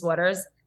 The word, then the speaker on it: Waters: Like, I'll be like, oh, my name's Alexis Waters. They'll be like Waddders. Every time.